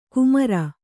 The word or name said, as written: ಕುಮರ